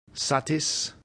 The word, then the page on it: satis: Latin